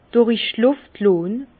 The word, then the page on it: durich Luft lonn: Bas-Rhin; Herrlisheim